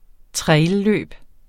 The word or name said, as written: trailløb